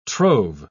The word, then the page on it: trove: British English